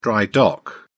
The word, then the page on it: dry-dock: British English